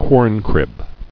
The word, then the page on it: Corncrib: corn·crib